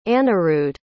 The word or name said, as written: Anirudh